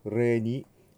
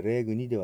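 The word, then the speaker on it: regni: エトイテルムベントゥルスエストクムグロリアユディカレヴィヴォスエトモルトゥオスクユスレニ、はい、 GN というのが出てくるとニャニニュニャニョの発音になりますね。したがってレグニではなくてレニになります。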